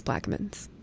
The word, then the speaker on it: Plaquemines: And they have a pronunciation for Plaquemines, but it's Plaquemines, which is hilarious. They have it spelled like P-L-A-C-K and hyphen M-I-N-Z-Z. And God bless them for trying, but everybody I know says Plaquemines.